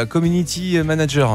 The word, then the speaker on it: community manager: Bah, ils ont ce qu'on appelle un community manager. Hein. Je l'ai mal prononcé. – Community manager.